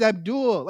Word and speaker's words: Avdol: His name is Abdul. Like they spell it as A-V-D-O-L, but Yeah, it's supposed to be pronounced that way.